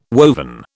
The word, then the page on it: woven: British English